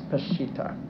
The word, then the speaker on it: Peshitta: I have great difficulty discovering how you pronounce that word, but I have found from an authoritative source that it's Peshitta.